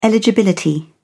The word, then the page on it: eligibility: British English